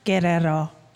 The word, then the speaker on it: Guerrero: It's Guerrero. It rhymes with Camaro, if that helps.